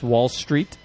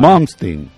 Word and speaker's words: Malmsteen: So it's pronounced Wall Street? – No. – That's fine. – Malmsteen. – Oh, Malmsteen.